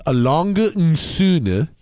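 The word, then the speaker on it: A. Lange & Söhne: And it's actually pronounced Alange and Söhne. It's written A Lang and Son.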